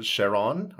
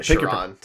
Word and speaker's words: Charon: Charon. – I was pronouncing it Charon. – Charon. There we go.